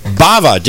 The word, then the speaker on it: Bava: His name is Staff Sergeant Daniel Bava. Did I say that right, Staff Sergeant? – You did, sir. Rhymes with lava.